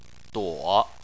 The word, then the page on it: duo: 3